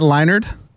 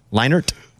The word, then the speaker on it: Leinart: am I saying it wrong? Matt Leinart? – Leinart. – Leinart. There's a T in there. – You're saying a D.